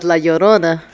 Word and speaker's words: La Llorona: Are you talking about La Llorona? – There you go.